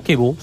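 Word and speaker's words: Chivu: Se pronuncia así, ¿no? Kibu. – Kibu, sí.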